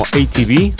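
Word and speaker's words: ATB: pronunciada tal cual como ATV, DJ productor musical, que se inició en la década de los 90, a principios de 1993.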